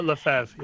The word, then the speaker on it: Lefebvre: Lefebvre, yeah.